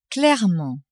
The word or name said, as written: clairement